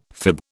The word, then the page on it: fib: British English